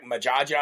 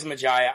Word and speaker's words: Majaya: Now, I have shouted them out before on the show, but I always was like, Majaja. I didn't know how to say it, but it is Majaya.